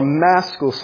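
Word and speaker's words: Maskil: So this is a mystical psalm. This is, or a maschal psalm is a way to better pronounce it.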